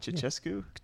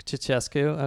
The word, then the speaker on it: Ceaușescu: Did you just say Cuscu? – Cuscu? – Ceaușescu? – Ceaușescu. Okay, Jesus. We are never going to have a Romanian on this podcast. I refuse to have my pronunciation corrected.